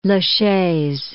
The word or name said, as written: Lachaise